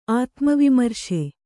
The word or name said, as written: ಆತ್ಮವಿಮರ್ಶೆ